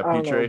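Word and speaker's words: Pitre: I like the versatility of how do you say his name? Petre? – Yeah, Petre.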